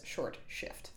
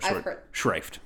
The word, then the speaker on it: short shrift: I think this is really commonly mispronounced as short shift. – Yes. – Or short shrift.